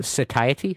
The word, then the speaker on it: satiety: Satiety.